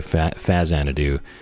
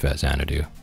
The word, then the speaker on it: Faxanadu: So Xanadu came out and it's pronounced Xanadu, and then this one came out on Famicom, so it's Famicom Xanadu, Faxanadu. So it would seem as though it is pronounced Faxanadu.